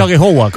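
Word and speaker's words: Aghehowa: No sé si lo he pronunciado bien, pero... he dicho Alejowa, ¿cómo se pronuncia? – Sí, bien, la verdad que... Bien, sí, así, muy bien.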